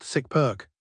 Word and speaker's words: SysRq: SysRQ, pronounced SICPERK, stands for System Request, a holdover from the old PC keyboard days.